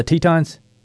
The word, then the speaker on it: Tetons: Am I saying that right, the Tetons? – Yep, you're saying it right.